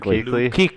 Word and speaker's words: Kuechly: Kuechly. How do y'all say it? – I say Kuechly. – Oh, y'all say it wrong. – Kuechly. – It's Kuechly. – Kuechly? – Y'all say it. – I've always said Kuechly.